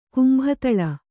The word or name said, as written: ಕುಂಬತಳ